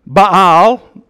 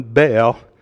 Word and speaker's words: Baal: Baal, two syllables, but we often pronounce it as just one, Baal.